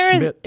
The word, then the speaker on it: Szmytz: Like Z-U-R-R. – Huh. – Yeah. – S-Z-M-Y-T-Z, but pronounced Schmidt.